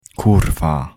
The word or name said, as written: kurwa